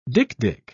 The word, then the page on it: dik-dik: British English